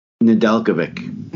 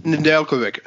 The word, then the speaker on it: Nedeljkovic: Alex Nedeljkovic. – Nedeljkovic. I knew I was saying that wrong.